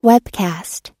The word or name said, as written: webcast